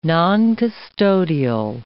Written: noncustodial

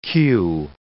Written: cue

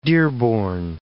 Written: dearborn

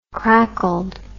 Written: crackled

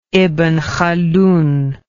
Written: ibn khaldun

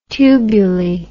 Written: tubuli

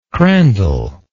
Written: crandall

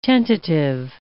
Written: tentative

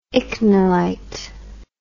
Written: ichnolite

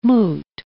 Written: moot

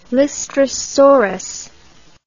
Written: lystrosaurus

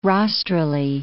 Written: rostrally

